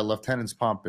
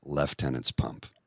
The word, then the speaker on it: Lieutenant's Pump: You said it correctly, sir, but you're military, so you know, Lieutenant's Pump. – Correct. – You know, that's how you say it.